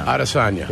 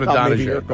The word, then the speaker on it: Adesanya: Adesanya. – Is how you say it. It's not Israel Adesanya. – No, it's not Adesanya.